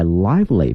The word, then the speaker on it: lively: 今天我们学一个词叫 live， L-I-V-E 后面加个 ly L-Y 哈 lively，不要把这个 L-I-V-E 呢念成 live，它是一个 live 后面加 L-Y lively，表示活泼的生动的哈。